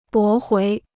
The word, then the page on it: 驳回: bó huí